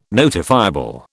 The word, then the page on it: notifiable: British English